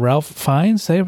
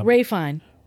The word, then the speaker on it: Ralph Fiennes: It's spelled Ralph, but pronounced Ray?